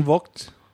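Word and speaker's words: Vogt: Is it pronounced Voight or Vought? – Jordan Voight.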